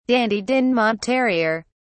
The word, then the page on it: dandie dinmont terrier: British English